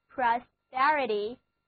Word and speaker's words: prosperity: 好了，我们回到 prosper 这个单词上来，我们在 prosper 后面加上 ity 的名词后缀，那么 prosperity, prosperity, prosperity, 注意重音后移了，一字母发作 a, 单词又读作 prosperity, 繁荣昌盛是一个名词。